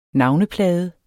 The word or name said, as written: NAVNEPLADER